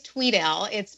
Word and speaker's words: Tweedale: Is it Tweedale or Twedale? – It actually is Tweedale. It's spelled phonetically incorrect. So it is spelled with one E, but it's pronounced as if there are two E's.